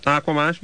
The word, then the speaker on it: Aquamosh: Now, is it Aquamosh, or should it be Aquamosh? – No, you just spell it right. – Aquamosh? – Yeah.